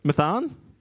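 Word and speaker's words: Matthan: and Eleazar the father of Matanah, and Matan? How would you say that? Mathan? Oh, there's a TH there.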